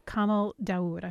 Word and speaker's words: Kamel Daoud: And this is a debut novel by Algerian journalist Kamel Daoud.